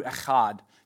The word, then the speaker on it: echad: This word one is the word in Hebrew, echad. You can say it like that.